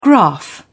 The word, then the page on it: graph: British English